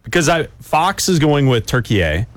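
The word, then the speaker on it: Türkiye: Pronounce it turkey A. – Turkey A. – It's pronounced turkey A.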